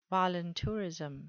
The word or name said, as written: voluntourism